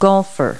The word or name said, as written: golfer